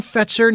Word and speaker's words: Fetzer: Fetzer, I believe that's how that's pronounced, Brian. Is that correct? – Mm-hmm, that's correct. – John Fetzer.